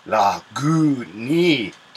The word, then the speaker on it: Lagunitas: And then under Lagunitas, it tells you how to pronounce it, but just says, say Lagunitas.